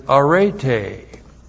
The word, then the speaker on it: arete: The Greek word for virtue is arete. A-R-E-T-E. long E. Arete.